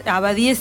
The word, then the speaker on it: Abadie: Si no, buscan a eh, Ricardo Abadie, es Abadie sin H, con acento en la E última,